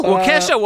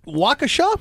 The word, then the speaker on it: Waukesha: Waukesha? – What the hell? – Waukesha, Wisconsin. – Waukesha? – Waukesha.